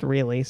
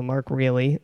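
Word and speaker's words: Reale: Pronounced really, so Mark Reilly, until his death in 2012. – I think it's Reilly.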